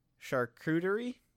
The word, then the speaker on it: charcuterie: You see it on a lot of charcuterie boards. – Oh. – I hope I'm pronouncing it correctly. – You are not, my friend. – Charcuterie? – Close enough. It's like charcuterie, yeah.